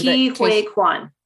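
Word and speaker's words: Ke Huy Quan: Ki Hui Kwan.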